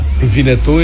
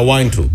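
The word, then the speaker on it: Winnetou: Vinetul este o pronunție nemțească, deoarece Carl May era neamț german. – Așa. Că altfel era Wine 2.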